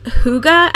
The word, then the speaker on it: hygge: One of the words that kind of sticks out is this term, hygge, I guess is how we figured out how to pronounce it. We actually looked it up to be sure we were getting it right, but it's spelled H-Y-G-G-E.